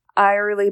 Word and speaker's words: Eyerly: Our next article, CEO has held leadership roles with ChildServe Eyerly Ball. It is spelled E-Y-E-R-L-Y Ball.